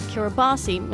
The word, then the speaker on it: Kiribati: There's a country in the Pacific called Kiribati. It's spelled like Kiribati.